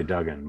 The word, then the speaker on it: Duggan: Is it Duggan or Dugan? Because we pronounce it in the family Duggan, but it's been pronounced a million ways.